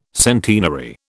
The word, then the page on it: centenary: British English